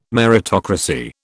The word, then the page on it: meritocracy: British English